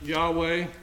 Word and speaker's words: Yahweh: We would pronounce it Yahweh in Hebrew.